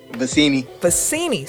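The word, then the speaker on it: Vecenie: I saw a tweet from Sam Benici. If I pronounce his name wrong, someone please correct me. But say it again. – Vecini.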